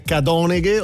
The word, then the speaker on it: Cadoneghe: L'accento tonico va sulla A. Dove va sulla A di Cadoneghe.